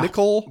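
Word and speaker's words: Nickal: Nickel? – Bo Dean Nickel.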